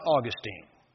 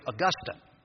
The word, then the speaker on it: Augustine: It is not Augustine. My Latin professor in seminary was the late Gleason Archer. And if you ever said Augustine, he would reprimand you severely by telling you that Augustine is a town in Florida where the PGA Hall of Fame is. Augustine was a church father. He was a stickler about pronunciation. So it is Augustine.